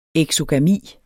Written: eksogami

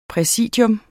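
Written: præsidium

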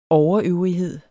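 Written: OVERØVRIGHED

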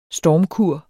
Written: stormkur

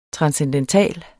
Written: TRANSCENDENTALE

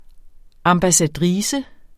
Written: ambassadrice

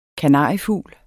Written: kanariefugl

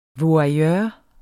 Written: voyeur

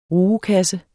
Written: RUGEKASSE

